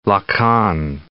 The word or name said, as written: Lacan